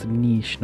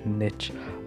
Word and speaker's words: niche: It's niche, because that's how you pronounce it. It's niche, not niche.